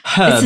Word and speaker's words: herb: Herb. – It's a silent H. Herb. – It's not silent because it's herb.